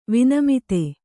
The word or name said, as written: ವಿನಮಿತೆ